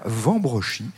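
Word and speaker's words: Wambrechies: Des choses magnifiques dans ce musée de la poupée et du jouet ancien, dans ce château de Robersart de Vambrechy, dont le nom s'écrit avec un W au début, mais on dit bien Vambrechy. J'ai vérifié, croyez-le bien.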